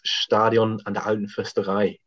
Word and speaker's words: Stadion an der Alten Försterei: I'm gonna go for the correct pronunciation. It's Stadion an der Alten Försterei. It's Union Berlin Stadium.